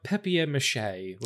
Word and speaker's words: papier-mâché: It looked like papier-mâché. Like, and yes, that is how you say it.